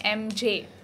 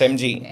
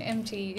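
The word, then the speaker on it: ENNJEE: Because most of them end up calling me MJ. – Mm. – MJ. I'm like, no. – No, no, it's MG. – MG, yeah.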